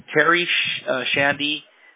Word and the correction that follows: Terry's Handyman: No, it's actually Terry's Handyman Repairs. – I don't know why I pronounced it like that.